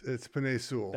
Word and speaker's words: Penei Sewell: It's Penei Sewell.